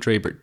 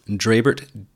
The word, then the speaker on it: Drebert: My name is spelled J-O-N-D-R-E-B-E-R-T, John Drabert, J-O-N Drabert, D-R-E-B-E-R-T.